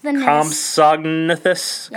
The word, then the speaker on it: Compsognathus: Compsognathus. – Compsognathus.